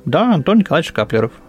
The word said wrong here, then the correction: Шкаплеров: или Шкаплеров, простите уж за мое невежество.